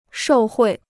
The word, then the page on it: 受贿: shòu huì